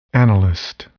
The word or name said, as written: analyst